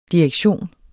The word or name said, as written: direktion